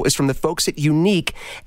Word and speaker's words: Yuneec: The company is called Unique. I love the way they spell it. Y-U-N-E-E-C. Unique.